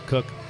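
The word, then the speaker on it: Koch: So the problem with Sam Adams, I would say, God, someday we'll get, how do you say this, Cook? – Cook.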